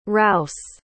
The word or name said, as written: Rousse